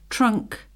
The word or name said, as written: trunk